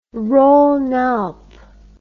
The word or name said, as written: Rhone Alpes